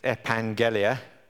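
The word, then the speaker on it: epangelia (ἐπαγγελία): I've stuck to the strong spelling there, which doesn't put the N in, but it's that double G is pronounced as an ung, so it's epangelia.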